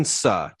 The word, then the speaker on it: Suh: I think there will be a very good rookie class, but Justin Chu. Is that how you say his name? Chu? – Nope, definitely not. Justin Suh. Justin Suh, folks.